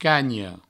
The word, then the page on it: canha: provençal; cagno